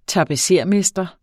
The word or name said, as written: tapetsermester